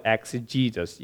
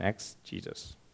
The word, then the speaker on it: exegesis: It's spelled E-X-E-G-S-I-S, and it's spelled exegesis, you know, Jesus and exegesis.